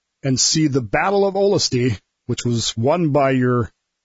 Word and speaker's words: Olustee: It's pronounced Olustee.